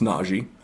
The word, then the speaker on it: Najee: But they brought Najee in, and it's actually pronounced Najee. I have learned that as well.